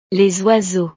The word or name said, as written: les oiseaux